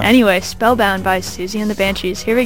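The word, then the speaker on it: Siouxsie: Anyway, here is Spellbound by how do you pronounce Susie and the Banshees. – That does not look like it would be pronounced as Susie. – It is.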